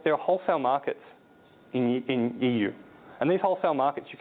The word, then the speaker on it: Yiwu: This little city called Yiwu, it's also pronounced EU, like the United, like European Union, EU.